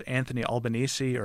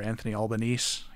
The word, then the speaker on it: Anthony Albanese: The new prime minister is Anthony Albanese, or Anthony Albanese. He says he doesn't care how you say it, apparently.